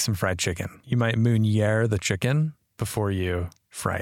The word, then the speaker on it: meunière: It looks like the word manure, and it might actually mean that as well. Might mean the same thing, but it's actually called, or it's pronounced meunière.